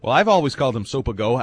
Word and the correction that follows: Sopoaga: I don't know why, just in my head. – Yeah. – And now it hurts me because that's not how you say it. – Yeah. – Sopoaga.